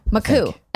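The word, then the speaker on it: Makuch: Wait, you told me how to pronounce his name properly. – Maku.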